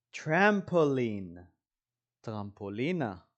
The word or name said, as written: trampoline